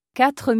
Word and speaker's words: quatre: Quatre minutes.